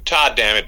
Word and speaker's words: Todd Dammit: Todd Dammit basically rhymes with God damn it, is basically where it came from.